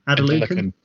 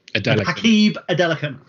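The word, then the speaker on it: Adelakun: I pronounce it Adelaken, but I'm sure it's Adelaken. – Adelaken. – Hakeem Adelaken. – Yes.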